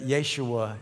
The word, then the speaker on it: Yeshua: Using the Jewish pronunciation of the name of Jesus, Lennox answered her, 'You know that I am a Christian. That means I believe that Yeshua is the Messiah.